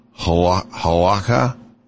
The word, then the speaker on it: halakha: Jewish law, halakha, did I say that right? – Yeah. Mm-hmm.